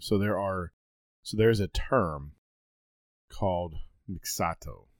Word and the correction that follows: mixtos: I think is how it's pronounced. Mixtos.